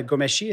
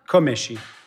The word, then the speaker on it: Comăneci: Non, puis tu as parlé aussi de Nadia Comăneci. Est-ce que je prononce bien? – Non. – Comăneci.